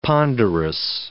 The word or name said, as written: ponderous